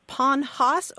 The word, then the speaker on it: panhas: P-A-N-H-A-S or... – Okay. – P-A-N-H-O-S-S. Some people call it Ponhos or Pony Horse.